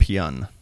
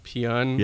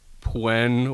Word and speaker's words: Pyun: I have to ask, how do you pronounce the director's last name? – I want to say it's Albert Pyun. – Pyun? – Yeah. – Okay, I didn't know if it was like Pwen or no.